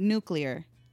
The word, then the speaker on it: nuclear: Okay, number five, nuclear. Am I saying that right? – Yeah, you're saying that right. But some people pronounce it nuclear.